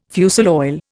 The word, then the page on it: fusel oil: Indian